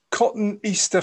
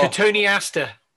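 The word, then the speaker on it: Cotoneaster: Cotton Easter Franchetti. – Oh. Cotone Aster. Cotone Aster Franchetti. – Cotone Aster. See, cotton Easter.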